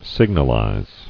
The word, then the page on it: Signalize: sig·nal·ize